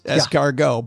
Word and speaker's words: escargot: There's a T at the end of that word. It's right there. – It is. – Why would we put it there if you're not supposed to pronounce it? It is, very good point. However, in this case, it is escargot.